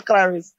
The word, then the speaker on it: Klaris: We will be back with the final segment of The Source today with Malik Brown, Atif Bostic, and Joshua Klaris. Say your last name for me again, please. – Klaris. But I like it with the Jamaican twang.